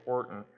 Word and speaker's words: Houghton: The president back then of China Inland Mission, the group founded by Hudson Taylor, asked a Anglican rector, his name sounds like, or it's spelled out like phonetically Houghton, but it's actually Horton.